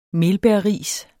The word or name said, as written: melbærris